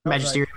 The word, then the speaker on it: Magisterium: Only went to the Magisterium, or the whatever it's called. – Yep, Magisterium, Mysterium, Sorcerer. – I said that right. Okay, you did.